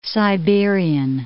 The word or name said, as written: Siberian